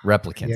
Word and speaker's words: replicant: It was, I watched it with two guys who loved the stories, and I love the idea of the relicants, right? Is that what it is? The pronounce replicants. – Replicants. Thank you. Replicants. I keep thinking relicanth, which is a Pokemon, and that's not it at all.